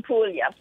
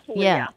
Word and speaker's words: Puglia: Puglia? – In Puglia, Puglia.